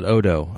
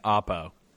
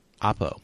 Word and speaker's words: Oppo: It's not Odo. That was just an inside joke because the packaging looks like the P's look like D's.